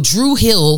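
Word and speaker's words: Dru Hill: So Drew Hill, D-R-U Hill is the name of the group, but that's how Druid Hill is pronounced in Baltimore because of, you know, the accent.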